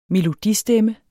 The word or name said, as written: melodistemme